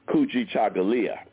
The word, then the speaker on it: Kujichagulia: The second principle of Kwanzaa is a principle called Kujichagulia.